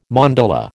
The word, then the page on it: mandala: American English